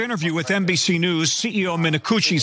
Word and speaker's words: Minicucci: Alaska Airlines CEO Ben Minicucci, that is his name, that is how you pronounce it, we did double check, is furious with Boeing, which manufactures all of the planes in Alaska Airlines' fleet.